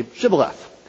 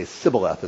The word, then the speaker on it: shibboleth: Uh, there's two uh, there's two letters in the Hebrew alphabet that look exactly the same, but they're pronounced differently. You just put a dot on one side or the other. But apparently the Ephraimites just couldn't pronounce this one letter so the one's a sh sound, the other's a s sound. So they say uh, they're asked to say shibboleth, but they say sibboleth instead, and that reveals them as Ephraimites.